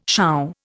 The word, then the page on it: sure: Indian